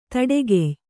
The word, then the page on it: ದಡಿಗೈಯ: Kannada